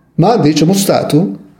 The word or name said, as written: mandi